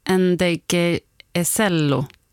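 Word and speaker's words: Ndegeocello: Michelle Ngeg-Joe. – Michelle. – Michelle Ngeg-Joe. – Nej, Ngeg-Ecello. Ngeg-Ecello, ja. Kanske.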